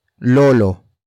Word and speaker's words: lolo: The next word is lolo.